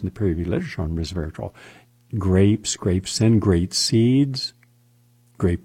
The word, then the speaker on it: resveratrol: Also, scientists at King's College London investigated resveratrol, three syllables, for its anti-hypertension properties.